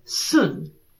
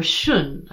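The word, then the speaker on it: Xun: So hexagram 57, Sun, or Shun, I think is actually how you'd pronounce it, because in modern transliteration, it's X-U-N.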